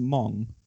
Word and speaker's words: Mong: M O N G. – Is that pronounced Mong? – Yeah, Mong. Like Mongo McMichael. – Okay. – But without the O.